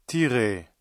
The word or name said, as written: tirer